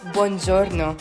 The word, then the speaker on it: buongiorno: It's pronounced buongiorno.